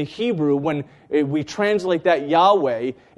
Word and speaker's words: Yahweh: Yahweh is the Hebrew, and Jehovah is another way to pronounce it.